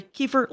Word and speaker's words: Kiefer: So just so everyone knows, Hallie Kiefer, it's Hallie like Halle Berry, Kiefer like Kiefer Sutherland, and all of my fan base, Rangers know those two people.